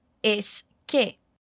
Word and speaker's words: es que: Es que. Repare que a pronúncia é como na palavra esquecer. Es que.